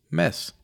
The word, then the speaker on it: Mads: Okay, so this is a conversation at a party hosted by Mads, and that name is spelled Mads, but as it's Danish, we don't pronounce the D for whatever reason, so it's Mads.